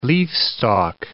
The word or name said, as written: leafstalk